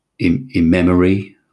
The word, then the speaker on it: Imemerie: And the village, it was mentioned in the Domesday Book, where it's called, and again, pronunciation here, but Immemerie, I think, I-M-E-M-E-R-I-E.